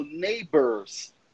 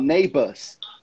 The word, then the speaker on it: neighbors: O inglês britânico, geralmente o R no final não é pronunciado. Então, eu não falo neighbors, que nenhum americano falaria. Eu falo neighbors. Então, não tem o R, não tem o som do R.